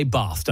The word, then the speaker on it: Bath: Yeah, so you say Bath, don't you? You pronounce it with the long A.